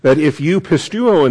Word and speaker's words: pisteuo: The Greek is pisteuo. That's the verb, P-I-S-T-E-U-O, long O with the omega. And then pistis. P-I-S-T-I-S.